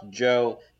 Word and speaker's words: Zhao: I think it's Zhao, but yes, I got you. – No. It's pronounced Joe, like the ZH.